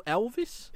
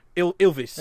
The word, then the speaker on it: Ylvis: But the band, is it, how do you pronounce it? Ylvis or Elvis? – Ylvis. – Ylvis, yeah. Right, okay.